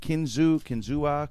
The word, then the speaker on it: Kinzua: Kinzua.